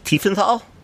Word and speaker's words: Tiefenthal: My number 8 is the Taverns of Tiefenthal, is how you pronounce it.